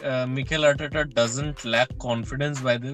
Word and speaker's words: Arteta: Arsenal coach Arteta? I am not sure if I'm pronouncing it right. – No, Mikel Arteta. Yes.